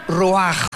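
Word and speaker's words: ruach (רוח): Y la palabra para espíritu en el versículo 29 es la palabra, se escribe ruach, como suena, con R de Raúl, CH al final, ruach, y se pronuncia ruach.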